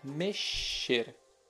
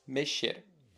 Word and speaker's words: mexer: Nesse verbo, a letra X deve ser pronunciada como as letras SH em inglês, como o início da palavra shark. Então pronuncia-se mexer, mexer.